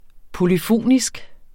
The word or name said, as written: polyfonisk